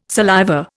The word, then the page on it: saliva: Indian